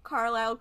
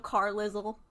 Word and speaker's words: Carlisle: Is this like when I first pronounced Carlisle Carlizzle? – Yes, it's exactly like that.